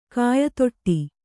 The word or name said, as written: ಕಾಯತೊಟ್ಟಿ